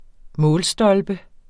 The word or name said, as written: målstolpe